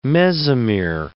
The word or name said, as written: mesomere